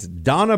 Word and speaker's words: Dana: It's spelled Dana, but she likes to be called Donna.